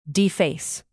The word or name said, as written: deface